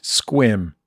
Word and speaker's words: Sequim: For his violent, albeit reasonable, mispronunciation of sequim. The E is silent. – Oh. – It's effectively a one-syllable word and is pronounced squim.